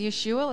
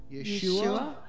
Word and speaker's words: Yeshua: So let's bless the Messiah, whose name is Yeshua. Let's all say His name. – Yeshua.